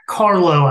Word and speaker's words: Carlough: All right, guys, tonight we have on Jack Carlo.